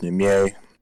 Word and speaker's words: Niemeyer: Yeah, it looks like Niemeyer. It's pronounced Niemeyer.